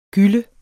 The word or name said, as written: gylle